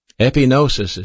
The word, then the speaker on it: epignosis: One of those words is spelled G-N-O-S-I-S, and it's pronounced gnosis. The other word is spelled E-P-I-G-N-O-S-I-S. That word is pronounced epignosis.